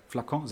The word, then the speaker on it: Flakon: Oder für eine Flasche, für einen Flakon. weißt du, also, spricht man das so aus? Ja. – Flakon, sehr schön.